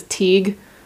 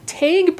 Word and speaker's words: Teighe: I had to Google how to pronounce it because I thought it was Teague. But no, it's spelled T-E-I-G-H-E, which is apparently pronounced Taig.